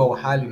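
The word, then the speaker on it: Al-Ahli: O Flamengo vence por 4 a 2 o Awali, no caso, o Al-Hali, né, a pronúncia,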